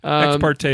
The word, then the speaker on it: ex parte: Ex parte is fine.